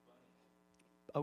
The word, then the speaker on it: Bani: I think it's pronounced Bunny.